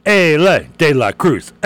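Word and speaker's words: Elly De La Cruz: Like, instead of, you know, like Ali Bumbaye, it's Ellie De La Cruz.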